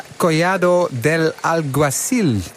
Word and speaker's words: Collado del Alguacil: Het is een loodzware bergetappe van bijna 187 kilometer van, moet ik het in mijn beste Spaans zeggen, La Calahorra na Collado del Alguacil. Toch, wielercommentator Andries Lamain? – Ja, toch, als in dat ik eventjes die uitspraak beoordeel. Nou, helemaal goed, helemaal goed.